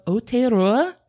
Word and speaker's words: Aotearoa: It is me and Christian here with our very good buddy, Thomas Rillstone from the History of Aotearoa.